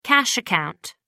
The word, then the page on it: cash account: American English